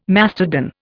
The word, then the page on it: mastodon: Indian